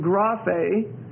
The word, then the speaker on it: graphe: Passa, P-A-S-A. Three Greek words. P-A-S-A, passa, and then graphe, G-R-A-P-H-E, and that E is a long E, so you put a long bar over it, G-R-A-P-H-E, and then theopneustos, T-H-E-O-P-N-E-U-S-T-O-S, T-H-E-O. P-N-E-U-S-T T-H-E-O-S, Theonoustos.